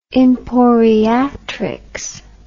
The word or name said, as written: emporiatrics